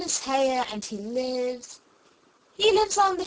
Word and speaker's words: Hare: His name is Hare." – He pronounced it so as to rhyme with mayor.